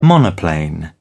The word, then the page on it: monoplane: British English